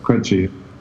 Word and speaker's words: Krejci: I think it's Krejci. He's a Czech guy. – Yeah, Krejci.